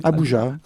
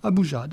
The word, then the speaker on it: Abuja: Alors, je ne sais pas comment le prononcer. – Abuja. – Abuja, d'accord. Oui.